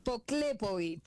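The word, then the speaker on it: Poklepović: Poklepovic. – Poklepovic. ¿Terminado en seca? – En C, pero es una C que tiene acento. Es una C croata, entonces se pronuncia como una Ch.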